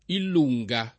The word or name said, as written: I lungo